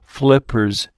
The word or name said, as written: flippers